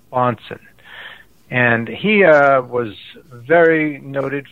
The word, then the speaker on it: Bahnsen: The word Bonson is spelled B-A-H-N-S-E-N, so it sounds a little different than it's spelled.